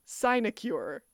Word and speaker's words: sinecure: Sinecure. Sinecure is spelled S-I-N-E, like sign in math, and then cure, C-U-R-E.